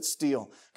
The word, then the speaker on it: stele: The Merneptah steel, that's how you pronounce that. It looks like stele, you pronounce it steel.